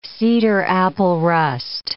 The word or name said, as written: cedar-apple rust